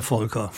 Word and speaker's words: Folker: Ich höre gerade, Sie sagen eher Volker. Ich habe jetzt eher so englisch ausgesprochen. Volker, wie ist es denn ganz korrekt? Ja, es gibt ja keine politisch korrekte Aussprache. Also wir tatsächlich sagen alle Volker.